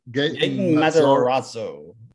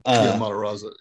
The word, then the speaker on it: Matarazzo: He's Gaten Matarazzo. – Matarazzo.